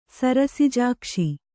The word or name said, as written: ಸರಸಿಜಾಕ್ಷ